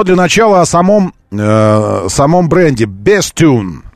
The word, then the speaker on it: Bestune: Бренд называется Bestune. Как бы best, лучший. А юн это как, ну, как юн, Bestune, вот и все.